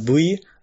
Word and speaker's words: AV: Also sie macht mittlerweile auch ziemlich viel halt außerhalb ähm, ja von AVs, das ist halt ähm, oder AV, wie man sagt im Japanischen, also Adult Video. Es ist nicht AV, sondern Bui, also das V wird Bui ausgesprochen.